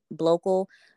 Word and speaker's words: Blocal: So Blocal is spelled B as in black, L O C as in cash, A L, and then it's the word search. And Blocal is a mashup of black and local.